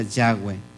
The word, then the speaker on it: Yahweh: Pues se le ponen ahí Yud Batkei se, se pronuncia Yahweh. Ese es su nombre.